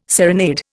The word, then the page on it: serenade: Indian